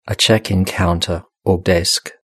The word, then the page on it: a check in counter or desk: British English